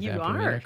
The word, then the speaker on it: Vampirina: Am I pronouncing Vampirina correctly? – You're one of the few people.